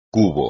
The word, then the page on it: cubo: espagnol